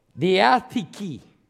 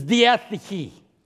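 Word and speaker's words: Diatheke (διαθήκη): How do you say covenant in the Greek? Diatheke. The Greek word for covenant, Brother Roy, is diatheke, from which translated means it does not in itself contain the idea of joint obligation.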